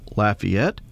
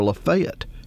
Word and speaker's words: Lafayette: Whether it is pronounced Lafayette, as it is in Louisiana, or Lafayette, as it is in Arkansas, the honor remains.